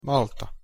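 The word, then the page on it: malta: Slovak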